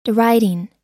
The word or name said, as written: deriding